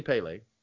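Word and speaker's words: Pelé: Oh, it's definitely Pele.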